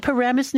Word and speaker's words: Paramus: Paramus, New Jersey, yes. Pronounced, by the way, through the nose, and I hope nobody there is offended.